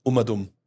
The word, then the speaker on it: Umadum: Ja, und das muss man auch wirklich so aussprechen. Es kommen viele und sagen, Umadum, das habe ich noch nie gehört. Ja. Es ist auf Gott bayerisch gesagt Umadum, also rundherum.